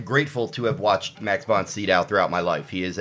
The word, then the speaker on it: von Sydow: I think you keep saying von Sydow. I think his name is pronounced Max von Sydow. That's that's how I've always said it, Max von Sydow.